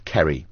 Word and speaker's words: Ceri: My real name is Kerry, but it's spelled C-E-R-I. And because it's Welsh, it's pronounced as though it was said with a K. – Uh-huh. – So it's pronounced Kerry.